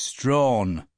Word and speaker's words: Strachan: "It's Strawn," said Meeker. "The CH is silent.